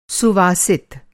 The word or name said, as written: सुवासित